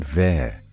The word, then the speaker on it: verte: Spearmint in French is pronounced menthe there and spelt menthe, M-E-N-T-H-E, and verte, V-E-R-T-E.